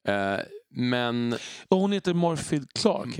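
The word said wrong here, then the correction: Morfydd: Heter ja, jag tror, att, jag tror att du uttalat Morwith. Ja, alltså, det, är det walesiska.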